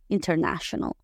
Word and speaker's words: international: Internacional se escribe igual, pero con T y le metemos flow. International, international, international.